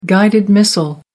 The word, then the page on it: guided missile: American English